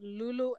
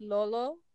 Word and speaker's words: Lolo: We've always pronounced it Lulu and Lula. It's actually pronounced Lolo and Lola.